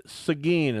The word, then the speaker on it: Seguin: Seguin is how they pronounce it. S-E-G-U-I-N.